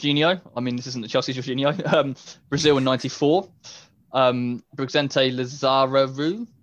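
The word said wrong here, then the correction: Lizarazu: Lazarazu, there we go.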